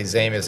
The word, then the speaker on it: Zaimis: My name is Harry Zaimis. So in English, you just say Zaimis to keep it simple, but it is Zaimis.